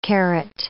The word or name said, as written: carat